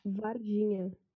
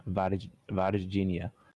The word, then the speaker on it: Varginha: Varginha. – Oh, it's like Virginia. Varginha.